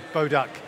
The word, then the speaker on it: Boduch: Boduch. Like Bo and then quack, quack, duck. – Boduch.